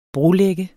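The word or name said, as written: brolægger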